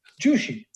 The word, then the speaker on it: Jushi: Well, first of all, it's easy to pronounce. It's Jushi with a J. Jushi.